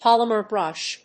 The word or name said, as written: polymer brush